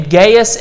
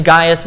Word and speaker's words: Gaius: Now, there's two pronunciations of that name, Gaius and Gaius. My son's name is Gaius, so that's the one you're going to hear all evening, even though I think Gaius is more of a traditional Roman pronunciation.